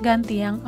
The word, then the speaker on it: Gantiang: Liebe Hörerinnen und Hörer, das Wort Ganting wird Gantiang auf der Minangkabau Sprache geschrieben oder ausgesprochen.